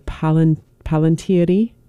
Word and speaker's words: Palantiri: So, you know, the reference, the Palantiri, is that how it's pronounced, from Lord of the Rings? – Yes.